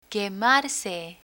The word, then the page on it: quemarse: Spanish